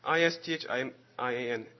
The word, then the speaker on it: Isthmian: 这个运动会叫做 Isthmian Games。 – 呢个运动会咧叫做 Isthmian。我把这个字拼给你们听哈，你们记一下啊，就是 I S T H M I A N。 – I S T H M I A N。 – 再讲一遍啊，I S T H M I A N。这个字念作 Isthmian、当时他们有这么样的一种的活动叫做 Isthmian Games。